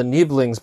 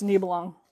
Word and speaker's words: Nibelung: Yes, um, because um, I don't think that's how you pronounce. I think it's Nibelung.